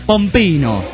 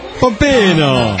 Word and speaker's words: pompino: Mamada, en italiano se dice pompino. – Pompino. – È giusto, Pompino? – Sì che sì.